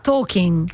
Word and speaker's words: Tolkien: Non lo chiamiamo Tolkien, in realtà si pronuncierebbe, pronuncialo tu. No, Tolkien sarebbe.